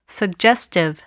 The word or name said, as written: suggestive